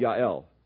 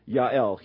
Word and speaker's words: Yael: And he came to the tent of a woman named Yael. You'll forgive me pronouncing the J as a Y, but Yael, Heber's wife.